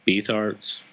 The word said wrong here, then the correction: Beathard: or uh, Beathard? – Beathard. Yeah, his injury really kind of changed the, the dynamic of what what what happened during that game, didn't it? – Yeah, and I mean, yeah, that'll that'll happen.